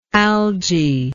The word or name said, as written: algy